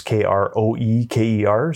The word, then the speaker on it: Kroeker: So online, I would say if you want to see our videos, just go to YouTube and look up Jeremy Kroeker. It's Kroeker. So that's partly why I started that YouTube channel, was just to teach people how to pronounce my last name. it's difficult to spell. It's Jeremy Kroeker.